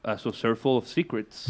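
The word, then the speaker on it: A Saucerful of Secrets: Luego en el 68, un año después, Pink Floyd, una banda muy, muy distinta, pues publicó su segundo álbum de estudio, A Surfful of Secrets. Uh-huh. ¿Cómo es? ¿Cómo se dice? – Como lo has dicho tú. Sí, sí, lo has dicho bien.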